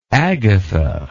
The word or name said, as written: agatha